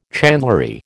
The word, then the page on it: chandlery: American English